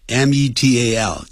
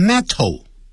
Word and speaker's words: metal: 他说将罐头 cans, bottles, 瓶子，这个不要念成 bottle 啊，那个八啊的音后的那个有这个 to 的音啊，像前面有这个元音的时候，像 metal, m e t a l 金属，不要念成 metal, 而叫 metal, metal, 舌头弹一下就好了。所以 bottles, bottles 不要念成 bottles 啊，不是这样念的。